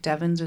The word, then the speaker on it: Devens: Devins is spelled like the number sevens, but with a D at the front, like Denver. So D-E-V-E-N-S.